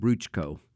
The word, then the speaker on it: Bruchko: is how the Multilone or Bari pronounce Bruce. They said Bruchko.